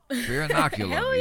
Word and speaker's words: Fear Inoculum: The new Tool album, Fear Inoculum. – Oh, my goodness. Hey, you can pronounce it. – Fear Inoculum.